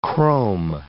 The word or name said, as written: chrome